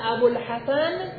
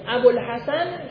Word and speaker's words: ابوالحسن: مثلا میگه قال ابوالحسن، ابوالحسن شما میگید نمیگید ابو، ابو الحسن، درسته؟ در نتیجه در تلفظ واو مقدره، اگرچه در کتابت ظاهره.